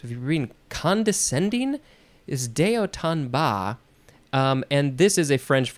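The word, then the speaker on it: de haut en bas: Okay, if we wanted to pronounce this in English, American English, we would say day hot and bass. That's how I would say it.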